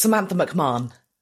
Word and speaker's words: Samantha McMahon: It's Samantha McMahon.